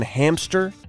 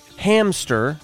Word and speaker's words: hamster: Did you know there's no P in hamster? It's hamster, not hamster.